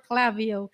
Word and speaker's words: Klaviyo: I looked that up, how to pronounce Klaviyo. Is it Klaviyo or Klaviyo? So it's Klaviyo.